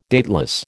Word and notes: dateless: American English

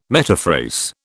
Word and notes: metaphrase: British English

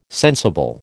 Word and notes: sensible: American English

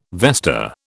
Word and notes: vesta: British English

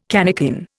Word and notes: cannikin: Indian

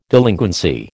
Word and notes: delinquency: American English

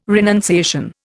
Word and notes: renunciation: Indian

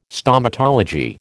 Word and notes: Stomatology: American English

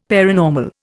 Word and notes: paranormal: Indian

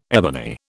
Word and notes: ebony: British English

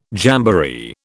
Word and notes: jamboree: British English

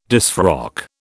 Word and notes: disfrock: British English

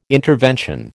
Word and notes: intervention: American English

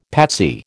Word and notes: patsy: American English

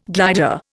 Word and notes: glider: Indian

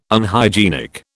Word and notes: Unhygienic: British English

